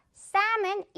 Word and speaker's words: salmon: 자, 이제 여러분, 외국 여행 가서 셀몬이라고 실수할 일 없겠죠? 만약에 여러분 친구들이 그렇게 셀몬이라고 실수하면 여러분이 직접 교정도 해주세요. 야, 그거 salmon 이렇게 발음하는 거야, 이렇게요.